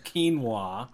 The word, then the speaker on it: quinoa: So, corn was their primary grain, along with a tiny grain called quinoa. – You mean quinoa. – Yeah. – Quinoa. So, in English, it's spelled Q-U-I-N-O-A, but it's actually pronounced quinoa.